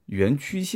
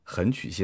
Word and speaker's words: 垣曲: 但是今天节目一开始呢，我要刊物一下上一期节目呢，我把这个贾跃亭第一次创业的所在地叫做垣曲县啊，结果我读成了横曲县。